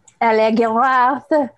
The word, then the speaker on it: Allegaroth: God, I can never remember how to say her name. The French one. – Allegaroth. – That's it.